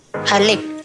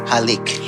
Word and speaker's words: halik: So, how do you say kiss in Tagalog? – Halik. – Halik.